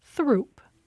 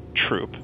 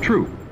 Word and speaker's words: Throop: Well, one of them runs through Bridgeport and other neighborhoods and looks like you might pronounce it Throop. – But most Chicagoans pronounce it Troop. So that's what we found in our research, and that's what we went with. – Troop.